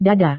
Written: dada

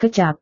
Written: kecap

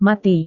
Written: mati

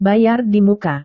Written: bayar di muka

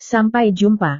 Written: sampai jumpa

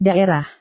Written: daerah

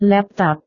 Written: laptop

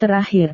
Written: terakhir

